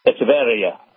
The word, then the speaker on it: Echeverria: Echeverria, probably, is a closer pronunciation.